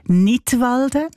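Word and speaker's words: Knidwalden: Unter einem Titel mit einem Wortspiel Nidwalden, ausgesprochen wie der Kanton Nidwalden, aber geschrieben mit einem K vorne dran, so wie beim englischen Wort für Lismen, to knit.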